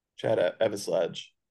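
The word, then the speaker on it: Chad Eveslage: How do you, Chad Evislage? Is that how you say it? – Chad Evislage.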